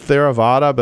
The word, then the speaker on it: Theravada: And I'll take a shot at pronouncing it Theravada, but that, I'm sure I've got that wrong. – You got it right.